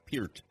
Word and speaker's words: Peart: Spelled Peart, but it's Peart of Rush.